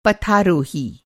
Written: पथारोही

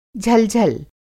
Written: झल-झल

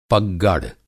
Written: पग्गड़

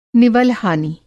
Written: निवल-हानि

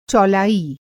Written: चौलाई

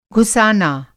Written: घुसाना

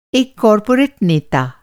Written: कारपोरेट

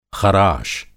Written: ख़राश

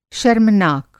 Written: शहंशाही

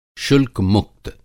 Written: शुल्क-मुक्त

